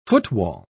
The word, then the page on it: footwall: British English